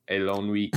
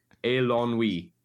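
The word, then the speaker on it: Eilonwy: Elongi is French. – Sounds French when I say it. It's meant to be Welsh. Tongue work. Elongi. That's better. More emphasis on the end.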